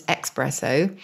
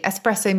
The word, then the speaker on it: espresso: So espresso, famously mispronounced as espresso. I think because people think it comes to you quickly or it comes out of the machine quickly. Espresso means pressed because the coffee grounds are pressed.